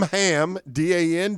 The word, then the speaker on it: Hamdan: H-A-M Ham, D-A-N, Dan. Hamdan. – It's spelled like Hamdan.